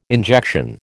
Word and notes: injection: American English

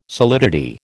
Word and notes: solidity: American English

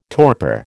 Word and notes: torpor: American English

